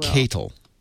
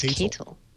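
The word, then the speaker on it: katal: It's pronounced Catal. – Oh, it's Catal.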